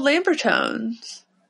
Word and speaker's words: Lambertones: Should we thank our sponsor, Lambertown? – Lambertown? What's with you and never getting his name right? – It's called Lambertones. – Lambertown Pickups. There's an S.